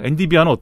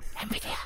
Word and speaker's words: 엔비디아: 어, 엔디비아는 어떡하지? 퀄컴은 어떡하지? 음. 다 미국 회사인가? 엔비디아. 음. 자꾸 내가 뭘 하려고 그러면 엔비디아. – 예. 왜냐면 자꾸 엔디비아라고 읽으니까 그러지. 엔디 엔디비아.